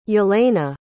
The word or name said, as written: Jelena